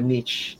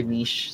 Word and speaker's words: niche: medyo niche, uh, niche ko or niche. So, depends on how you pronounce it.